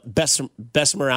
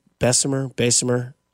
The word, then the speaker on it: Bessemer: Bo Jackson is from Bessemer, Alabama, I believe is how you say it. Bessemer, Alabama.